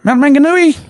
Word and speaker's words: Mount Maunganui: But yes, he's from Mount Maunganui, wherever the hell that is. – Mount Maunganui, dude. You got to say it like it, Kiwi. Mount Maunganui.